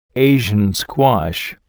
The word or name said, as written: Asian Squash